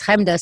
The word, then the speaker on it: Chemda: My name is Hemda, so if you know how to spell that, you can find anything I've ever done just by first name. It's spelled Chemda.